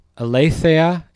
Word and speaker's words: Aletheia: Aletheia is the Greek word, A-L-E-T-H-E-I-A.